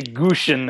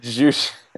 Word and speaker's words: Jushin: You said his name right. Yeah. – I didn't say Gushin this time. I said Jushin.